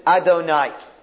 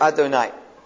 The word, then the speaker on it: Adonai: When we see that name, we pronounce it not the way it's written, not the way it's spelt, but we pronounce it Adonai. Okay? We pronounce it Adonai.